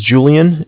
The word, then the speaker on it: Julian: Okay, my name is Julian. It's J-U-L-I-A-N. – Okay. – And I'm calling from Bank of Cardiff. It's spelled C-A-R, D as in dog, I-F-F as in Frank Frank.